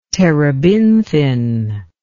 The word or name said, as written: terebinthine